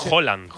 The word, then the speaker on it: Haaland: Es Haaland. – Haaland. Como si dijeras un queso, ¿no? – ¿Sabes qué pasa el martes?